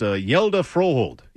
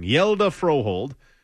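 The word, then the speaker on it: Hjalte Froholdt: His name is apparently pronounced Yelda Froholt. Even I had it a little bit wrong. Yelda Froholt.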